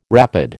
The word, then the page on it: rapid: American English